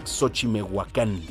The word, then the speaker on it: Xochimehuacán: Se pronuncia Xochimehuacán, discúlpeme, es que por la prisa de la lectura, Xochimehuacán, ahí en, San Pablo, Xochimehuacán.